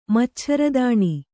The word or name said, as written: ಮಚ್ಛರದಾಣಿ